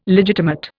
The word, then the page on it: legitimate: Indian